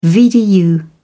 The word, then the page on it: VDU: British English